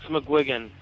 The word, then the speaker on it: McGuigan: It's McGuigan.